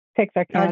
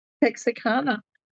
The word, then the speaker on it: Texarkana: And you're from Texarkana, is it? How do you say that? – Yes, ma'am. Texarkana. – I did it right. Texarkana.